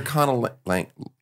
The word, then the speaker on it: conlanger: So you are a cunnilingus. Am I saying that right? What is it? No, it's not. Conlanger or Conlanger. – Conlanger? – Conlanger.